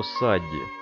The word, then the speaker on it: Садди: Я спросила, как же мне называть его, и он разрешил звать его Садди. Это было имя для игры, возможно, прозвище. Ударение тоже на последнем слоге.